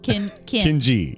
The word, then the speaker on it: Kinji: Well, if every time I'm feeling like I need to make an exclamation about a mess, I'll just think of Kimji. – Kinji. Kinji. I really missed it. Yeah, I said it wrong right. – on both sides.